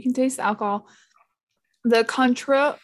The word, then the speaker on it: Cointreau: Cointreau. It's an orange liqueur. – Conture. – I've been told. – That's how the French say it.